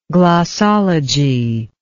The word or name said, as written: glossology